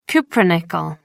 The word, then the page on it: cupronickel: American English